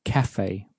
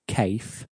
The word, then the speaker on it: café: And this café e is pronounced in the same way it is in English. So we say café, we don't say cafe. So that E on the end has a sort of an A sound in café.